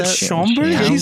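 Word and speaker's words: Schaumburg: The MMSI, Military Miniature Society of Illinois, it is happening in the Marriott in Shambaugh. Anyway, October 20th to 21st. So that Friday and Saturday. – Can we get an actual pronunciation? – Is it Schomburg? – Schomburg. Yeah, I can't.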